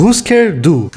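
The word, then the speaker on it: Hüsker Dü: Então, calma aí, que na verdade a pronúncia é Husker Du.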